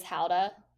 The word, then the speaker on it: Gouda: That's like Matt, like he pronounces Gouda cheese Howda. – Howda would be like, what the hell is Howda? – That's like the Dutch way of saying it.